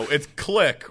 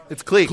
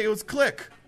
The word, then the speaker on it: clique: Isn't it just said clique? That's how it's pronounced, isn't it? – I'm panicking now. It's click, right? Yeah, everyone's shaking their head. Everyone's on their head at me. – It's clique. – Clique? What? I had someone say it was click. – Well, it's regional.